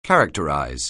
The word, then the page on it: characterize: anglais